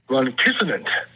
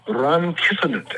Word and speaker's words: rhonchisonant: Ronkissonant. Ronkissonant. And it's spelled R-H-O-N-C-H-I-S-O-N-A-N-T. Ronkissonant. It's an adjective, and it means making a snorting sound.